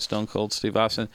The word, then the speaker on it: Stone Cold Steve Austin: Stone Cold Steve Austin.